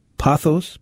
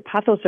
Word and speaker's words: pothos: This listener sent a text that said, I was recently in Florida and brought back, is it pronounced pothos? Pothos? – Pothos? Pothos vine, yep.